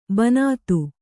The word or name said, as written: ಬಿಣ್ಣಿತು